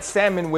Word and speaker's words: salmon: Well, listen, as I was eating my salmon, that salmon without the L is silent, I'm looking at Dak, I'm looking at Zeke Elliott, and we get a quick pitch, right?